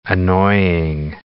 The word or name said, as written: annoying